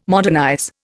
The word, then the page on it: modernize: Indian